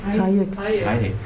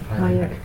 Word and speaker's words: Hayek: Hatsche Gesellschaft. – Hayek. – Hayek. Entschuldigung, falsch ausgesprochen.